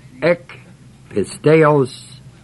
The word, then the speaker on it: ek pisteos: The Greek words, of course, you could look up in any interlinear, but they are ek pisteos, eis pistin, E-K-P-I-S-T-E-O-S, long O, E-I-S-P-I-S-T-I-N.